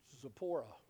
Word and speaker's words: Zipporah: One of these women was a woman named Zipporah. That's the way I pronounce it.